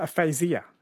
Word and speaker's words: aphasia: Okay, so aphasia was the answer. Aphasia. Apparently it is pronounced.